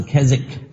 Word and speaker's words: Keswick: 第五波呢叫做凯西克，凯旋的凯啊，无锡的锡啊，克服的克，凯西克 Kesick 这个字是 KESWICK KESWICK 这个 W 是不发音的啊，不是 Keswick 啊，是 Kesick。